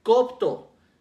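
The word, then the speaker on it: copto (κόπτω): copto, copto, igual con K y con acento en la primera O, que dice el diccionario enciclopédico de concordancia exhaustiva strong, que significa golpearse el pecho en aflicción, hacer lamentación.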